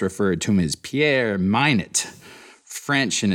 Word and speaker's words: Minuit: A remarkable one stepped forward, a man named Pieter Minuit, spelled M-I-N-U-I-T. German was Minuit's first language, and Dutch was his second, but his ancestry was French. Shorto suggests that he probably pronounced his last name the French way, Minuit, as I will do, because Shorto certainly knows a lot more than I do.